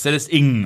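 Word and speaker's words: Celeste Ng: Celeste und, man hat mir gesagt im Verlag, das schreibt sich ja NG, das können wir beide nicht aussprechen, selbst wenn wir uns mühen. Man würde es Celeste Ingen aussprechen.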